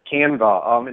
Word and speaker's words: Canva: Canva. It's like canvas without the S.